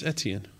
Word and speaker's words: Etienne: Travis Etienne. Is that how you pronounce his name? – Yeah, Travis Etienne.